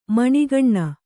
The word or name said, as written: ಮಣಿಕಣ್ಣು